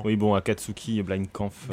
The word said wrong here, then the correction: Blitzkampf: Blitzkampf. – Blitzkampf.